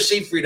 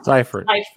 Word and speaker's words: Seyfried: Is it Seyfried or Siegfried? I never can say it right. – Seyfried. – Seyfried. We've been mispronouncing it for 15 years.